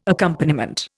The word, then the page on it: accompaniment: Indian